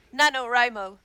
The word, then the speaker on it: NaNoWriMo: How do you pronounce the acronym for National Novel Writing Month? – NaNoWriMo. – Okay. I always pronounce it NaNoWriMo. – And you're wrong.